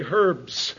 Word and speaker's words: herbs: He that is weak eats, and it's becoming popular to pronounce the H again and say herbs. Well, I'm old enough to come from the generation where you never pronounced the H, and you had to say herbs.